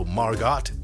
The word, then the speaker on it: Margot: Margot," she said, "No, the tea is silent, as in Harlow."